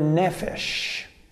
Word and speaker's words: nephesh: The Hebrew word that is translated being in the New International Version and many other versions is the word nephesh. Nephesh, and I'll spell that for you, N-E-P-H-E-S-H, N-E-P-H-E-S-H, nephesh.